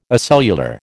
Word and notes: acellular: American English

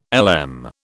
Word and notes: LM: British English

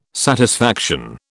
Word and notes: satisfaction: British English

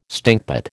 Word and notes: stinkpot: American English

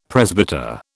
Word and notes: presbyter: British English

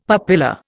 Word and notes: papilla: Indian

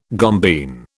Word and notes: gombeen: British English

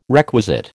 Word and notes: requisite: American English

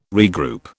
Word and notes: regroup: British English

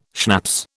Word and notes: schnapps: British English

